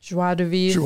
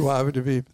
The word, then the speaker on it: joie de vivre: Uh, joy de vivre is how you say it. Joie de vivre. Joie de vivre. Thank you.